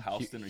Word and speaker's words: Houston: Houston or Houston? – Houston. – It's Houston in New York.